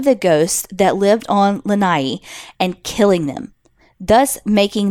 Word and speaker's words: Lanai: I live in Hawaii on an island called Lanai, more commonly mispronounced as Lanai.